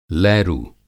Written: लैरू

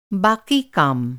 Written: बाक़ी

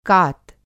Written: कात

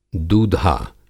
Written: दूधा

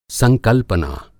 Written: संकल्पना

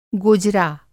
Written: गोजरा

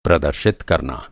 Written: प्रदर्शित

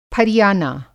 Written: फरियाना

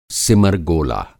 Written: सिमर गोला

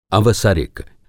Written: अवसरिक

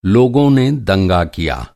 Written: दंगा

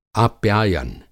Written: आप्यायन

